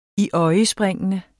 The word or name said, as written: iøjnespringende